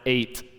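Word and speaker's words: eight (עת): It's pronounced eight in Hebrew.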